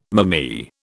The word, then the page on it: mammee: British English